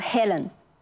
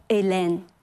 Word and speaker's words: Helen: 有一个人名英语当中读 Helen，到了法语当中呢，它还是这样写，但是它就要读作 Elen，那么海伦就变成了伊莲。